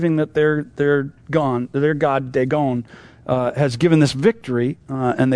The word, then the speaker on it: Dagon: Now, Dagon, we call it Dagon, is the pronunciation of it, was the god that was the primary god of the Palestinians, as far as we understand it.